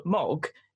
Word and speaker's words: Maug: So obviously how it's spelt, so for Mog, M-A-U-G, I would pronounce that as Maug, as in Smaug, but because that's just what I've been brought up with.